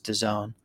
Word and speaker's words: DAZN: This is the company D-A- DAZN, or uh, pronounced DAZN.